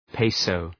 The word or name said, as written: peso